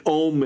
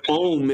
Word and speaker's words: Om: Well, I don't want to be around the chanting of Om because that word Om is an invocation to three deities. It's actually three syllables merged together as one. A-U-M.